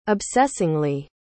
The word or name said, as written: obsessingly